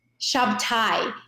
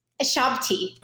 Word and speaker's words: Shabti: Did I pronounce your name right? That's the first thing I'd like to cover here. – Shabti. – What did I say? – Shabti. – You know why? Because I asked you phonetically how to spell it, how to pronounce it earlier on, and then I wrote it incorrectly.